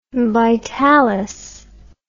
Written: Vitalis

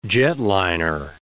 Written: jetliner

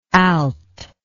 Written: alt

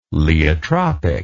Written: laeotropic

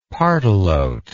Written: pardalote

